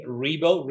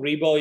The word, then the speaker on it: Ribo: Ribo. Ribo is spelled R-I-B as in boy, O. Angel is like an angel from heaven, A-N-G-E-L, right?